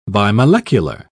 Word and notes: bimolecular: British English

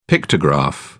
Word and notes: pictograph: British English

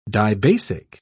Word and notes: dibasic: British English

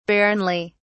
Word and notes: barrenly: British English

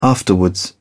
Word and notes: afterwards: British English

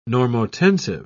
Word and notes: normotensive: British English

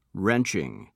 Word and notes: wrenching: American English

